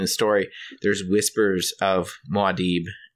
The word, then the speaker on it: Muad'Dib: I call it Muad'Dib. – Muad'Dib. I say Muad'Dib. Okay. – And Muad'Dib. So, Muad'Dib? That's better. – That's Muad'Dib.